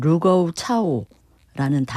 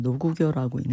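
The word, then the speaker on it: 루거우 차오라는 (盧溝橋): 중일전쟁이 이제 발발하는 게 1937년 7월 7일 베이징 근처에 이 루거우 차오라는 다리 이름이에요. – 네. – 노구교라고 한자로는 우리가 읽을 때는 노구교라고 읽는데 여기 이 다리 부근에서 일본군 병사 한 명이 실종이 돼요.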